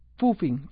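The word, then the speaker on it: phubbing: Y bueno, la otra práctica relacionada con las nuevas tecnologías es el famoso poofing. Yo les digo que se escribe P-H-U, viene de W-I-N-G,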